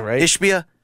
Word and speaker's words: Ishbia: Ishbia.